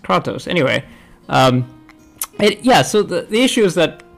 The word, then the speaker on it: Kratos: They say it in game, and it's Kratos, my dude.